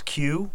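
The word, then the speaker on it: queue: So what word begins with a Q and is followed with four silent letters? – It is... – we had this before. – Yes. And I want to say it's Q, like a line. – Yeah. And you spell it? – Q-U-E-U-E-U-E-U-E.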